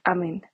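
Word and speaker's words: Amen: Para esta tercera temporada y esta introducción, quiero hablarles del doctor Amon, que se escribe amén, como decir amén.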